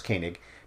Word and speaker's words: Koenig: The other actors, James Doohan, Walter Koenig, apparently it's pronounced Koenig,